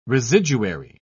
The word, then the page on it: residuary: British English